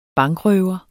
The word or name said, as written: bankrøver